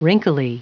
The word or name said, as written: wrinkly